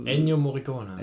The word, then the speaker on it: Ennio Morricone: Theme von Enrio Cor Ennio Morricone.